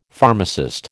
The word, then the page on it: pharmacist: American English